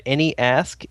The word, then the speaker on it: AnyAsq: But somebody started a website, AnyAsk, A-N-Y-A-S-Q. Is that correct, Joel? Let me make sure I'm saying this correctly. AnyAsk. Yeah, there it is.